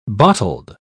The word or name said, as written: bottled